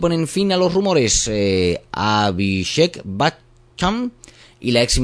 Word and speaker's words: Abhishek Bachchan: Abhishek Bachchan y Aishwarya Rai, muy bien, así más auténtico, pues tenían un romance en secreto.